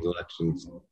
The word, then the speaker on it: Lutyens: Lutyens, okay. The T is a C-H? – Oh, sorry. – Even though it's spelled Lutyens, he pronounced his name Lutyens.